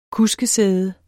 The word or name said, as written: kuskesæde